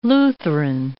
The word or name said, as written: Lutheran